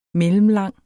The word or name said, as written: mellemlang